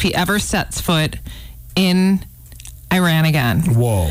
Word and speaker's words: Iran: Is that how you say it? – Iran, you got it. Not Iran, Iran. – Okay, Iran.